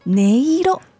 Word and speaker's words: 音色: これは音と色。で、音色と読みます。